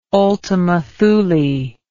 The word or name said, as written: ultima Thule